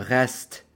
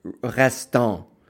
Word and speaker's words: restent: The common mistake would be to pronounce that ENT restant, or so that's completely wrong. So don't pronounce the ENT on a verb.